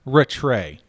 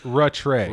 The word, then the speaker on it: Rattray: That's how you pronounce it, Rattray. – I'm so glad you said that, because I've been saying Rattray in my head all day. And I'm like, there's no way that that's last name. – It actually says it's pronounced Rattray.